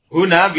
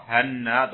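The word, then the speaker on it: Huna (هنا): Wa bi fathiha boleh dibaca fathah berarti apa? Wa kasriha ma tasdidin nun. Kalau di fathah atau kasrah dengan ditasdid nun berarti apa bacanya? Hanna hinna. Baik. Ini saya ulangi. Huna bi dhammil ha wa huwa ismu isyaratin lil makanil qarib kita sampai situ ya. Kalau dibaca fathah atau kasrah, hanna atau hinna lil makanil ba'id.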